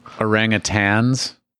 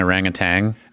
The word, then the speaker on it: orangutan: There's no G at the end. It's orangutan.